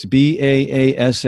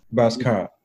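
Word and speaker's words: baasskap: It was a government system characterized by an authoritarian political culture based on, you got to help me with this pronunciation, BASKAP. It's BASSKAP. Mm-hmm. BASKAP or white supremacy. Mm-hmm. Do you know how to say that? – BASKAP.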